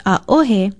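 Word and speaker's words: aohe (ʻaʻohe): No, it's not a mispronunciation. Aohe, spelled Okina, A, Okina, O-H-E, and pronounced aohe, means no, too, but it is generally used to mean none.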